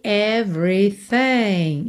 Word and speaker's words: everything: Você não pronuncia o segundo E de everything. Então, do V vai pro RI, já. Everything. O G final também não é pronunciado.